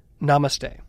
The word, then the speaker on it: namaste: So one of the popular terms that happens too in yoga studios is namaste. – Yes, it does. That, I pronounced that correctly, right? – You did.